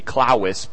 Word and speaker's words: clavis: Well, the Latin word is clavis. Or if you wanted to say it, the V's are pronounced with W's, it would be clavis, but clavis is key, clavicle, key.